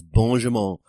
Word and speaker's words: Benjamin: I'm loving the way that the French pronounce Benjamin. It's Benjamin.